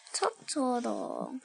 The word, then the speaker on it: Totoro: Totoro.